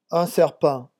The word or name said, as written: un serpent